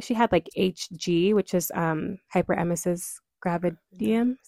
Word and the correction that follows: gravidarum: something like that. Am I saying it right, Mom? – Gravidarum.